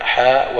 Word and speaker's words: حاء: المغاربة يقولون هذه الحاء معناها الحديث، اختصار لكلمة الحديث، والأكثر على أنها حاء تحويل وتقرأ مفردة هكذا حاء ويمر، يعني بسرعة حاء وحدثنا، وشخص يقرأ في كتب المصطلح عجز أن ينطق بها، عجز أن ينطق كلمة حاء ويمر.